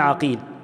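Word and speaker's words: عُقيل: حصل الاختلاف في الآباء من حيث النطق فقط، زي مثلا محمد بن عقيل بفتح العين، محمد ابن عقيل، ومحمد ابن عقيل بضم العين.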